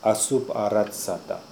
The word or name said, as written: Assooparatsata